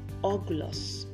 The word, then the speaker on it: orgulous: For this week's word, it's pronounced Oglos. Oglos. And it's spelled O-R-G-U-L-O-U-S.